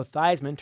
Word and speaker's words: Theismann: In the modern era, when Notre Dame's Joe Theismann started pronouncing his name Joe Theismann to rhyme with Heisman,